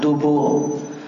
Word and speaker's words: dubur: Ya, Ibnu Hajar juga mengatakan tentang dubur, itu cara bacanya dengan dua dhammah, dubur. Al-Azhari mengatakan duburul amri artinya bidomatain, pakai dua dhammah.